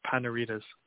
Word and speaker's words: Panaritis: It's pronounced Panaritas.